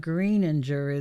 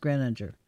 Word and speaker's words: Greninger: Greninger is what it looks like, but it's pronounced Greninger.